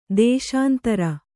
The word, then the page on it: ದೇಶಾಂತರಿ: dēśāntari